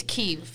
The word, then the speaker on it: Kyiv: Yeah, so for them, it's Kyiv.